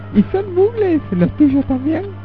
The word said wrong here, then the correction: Muggles: ¿Mugles, hijo? – ¿Cómo se pronuncia? – Muggles. – Ah, bueno, usted porque es el que habla inglés. Son muggles.